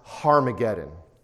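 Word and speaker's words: Harmageddon (Ἁρμαγεδών): And in Greek, there's a rough breathing mark over Armageddon, so it's literally pronounced Harmageddon, okay?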